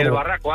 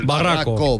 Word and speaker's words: El Barraco: Desde el Barraco, Ávila. – El Barraco. Barraco, Barraco. Vale, vale, vale. – Lo he dicho bien. – Lo ha dicho bien.